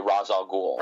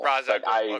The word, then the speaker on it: Ra's al Ghul: how do you say it? Raish? Raal? Raals? Raz? – I say Ra's al Ghul. – Ra's al Ghul, okay.